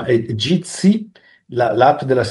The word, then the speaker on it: Jitsi: Meet Jitsi, Jitsi Meet, e si scrive J come Juliet, Imola Torino, JIT.SI, Savona Imola, però uno se lo cerca con Jitsi, con la J, lo trova.